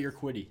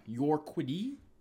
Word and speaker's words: Urquidy: No, Yarbrough is pitching tonight versus Urquidy, or however you pronounce his last name. – Oh, Jose Urquidy. – Yeah. Yeah. – Urquidy? – Yeah.